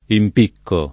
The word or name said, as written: impiccare